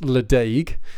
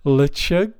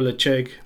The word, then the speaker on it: Ledaig: So it's spelt like it looks like it should be pronounced Ledaig, but I believe it's pronounced Lechug.